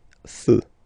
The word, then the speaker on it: feux: You don't put S, but you will put X instead, and then you don't pronounce it. Des feux.